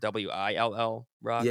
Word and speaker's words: Will Rock: Is it pronounced 95 Will Rock or WILL Rock? – Yeah, WILL. Yeah, so it's Wisconsin and Illinois right there on the border. So it's Will Rock.